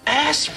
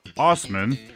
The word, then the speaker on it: Assman: ASSMAN. Yeah, he's the ASSMAN. – Now, to be fair, he says it's pronounced Osman.